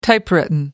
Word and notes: typewritten: American English

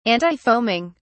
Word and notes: antifoaming: British English

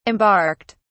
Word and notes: embarked: British English

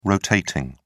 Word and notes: rotating: British English

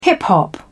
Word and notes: hip-hop: British English

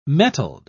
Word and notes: mettled: British English

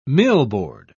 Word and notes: millboard: British English

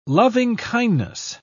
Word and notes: loving-kindness: British English

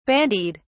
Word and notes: bandied: British English

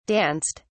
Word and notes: danced: British English

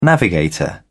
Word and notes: navigator: British English

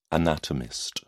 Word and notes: anatomist: British English

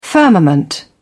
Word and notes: firmament: British English

